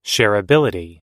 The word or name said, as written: shareability